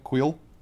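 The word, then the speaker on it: Kuiil: Quill. – Yep. – Quill is great. – Like the pen.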